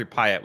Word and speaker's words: Pyatt: Pratt. Jeffrey Pyatt, it's spelled. – Yeah. – Pyatt, P-Y-A-T-T, right? So, mm-hmm.